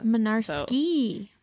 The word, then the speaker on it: Mynarski: Is Mynarski phonetically spelled? Like, does it? Because to me, that sounds like it could have like a silent G in it. I don't know. – No G's. It is spelled with a Y, though. In the Mynarski. – M-Y-N-A-R-S-K-I. – Oh, not where I thought the Y was. – K-I. Yeah. – Okay, no. – It's Polish.